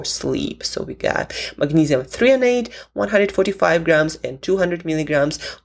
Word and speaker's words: threonate: 145 milligrams of magnesium threonate, so threonate, that's how you pronounce it, 145, and then we have 200 milligrams of magnesium bisglycinate.